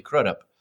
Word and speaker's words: Crudup: Billy, how do you pronounce his last name? Crudup? – Billy Crudup? Oh, yeah, yes. Billy Crudup.